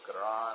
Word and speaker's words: Quran: And if you say qara'a, or ah, it sort of in English sounds like Quran. I'm not really pronouncing it well, but it does somewhat. And that's where we get the word.